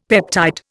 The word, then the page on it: peptide: Indian